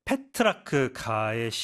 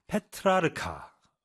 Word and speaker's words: 페트라르카: 이 O'Driscoll에 따르면 타로는 원래 브릿지와 비슷한 카드 게임에 사용했지만 이때 게임의 개념은 부분적으로 이탈리아 르네상스 시인인 페트라크가의 시 승리, 뭐 triumphs에서 영감을 받았는데 이 작품은 도덕적 위계 질서를 배열한 14세기의 우아시였습니다. 그렇죠? 페트라르카.